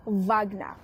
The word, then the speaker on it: Wagner: It's pronounced Wagner.